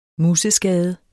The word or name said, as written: museskade